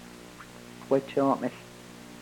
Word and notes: pkwechoomehl: Yurok

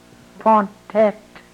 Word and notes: pontet: Yurok

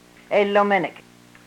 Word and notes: elomenek: yurok

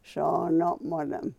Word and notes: soonomolem: yurok

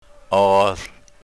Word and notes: oohl: yurok

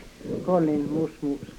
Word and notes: kolin musmus: yurok

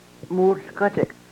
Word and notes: muhlkochek: Yurok

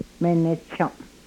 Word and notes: menechom: Yurok

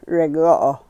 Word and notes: regoo: yurok

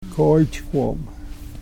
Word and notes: kooychkwom: Yurok

